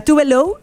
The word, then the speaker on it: Tove Lo: Se llama Tove Lo, o sea, Tove Lo, es la pronunciación, pero su nombre real es Eva Tove Elsa Nilsson.